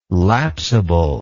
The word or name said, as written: lapsible